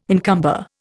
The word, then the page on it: encumber: Indian